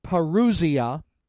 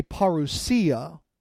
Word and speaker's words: parousia: You most often will hear it pronounced parousia. Some people, keeping the Greek pronunciation, will say parousia.